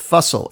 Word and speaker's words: Fussell: Now, Jake Xerxes Fussell, and yes, that is his real name, and it's pronounced Fussell. It's spelled with an F. It's not Russell.